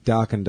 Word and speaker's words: Dokken: Dokken doesn't rhyme with metal. It rhymes with rockin'.